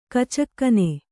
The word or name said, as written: ಖಚಕ್ಕನೆ